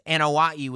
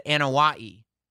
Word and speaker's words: Anoa'i: And he said Anoa'i. And I went, that's how you pronounce that name. It's not Anoa'i. Anoa'i.